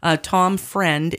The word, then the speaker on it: Freund: Tom Friend is his friend, but it's spelled F-R-E-U-N-D.